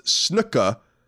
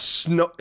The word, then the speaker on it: snooker: Snooker is pronounced snooka, like snooka, not snooker.